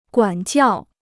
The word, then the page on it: 管教: guǎn jiào